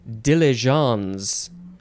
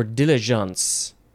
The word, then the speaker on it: diligence: I pronounce it wrong. It is diligence or diligence. This is French, and it literally means haste.